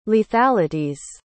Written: lethalities

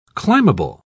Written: climbable

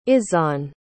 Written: izhaan

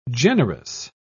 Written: generous

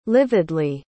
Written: lividly

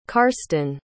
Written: carsten

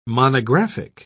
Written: monographic

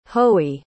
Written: hoey

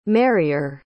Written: marryer

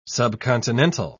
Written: subcontinental

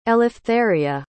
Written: eleftheria

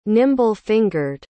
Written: nimble-fingered

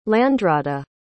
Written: landrada

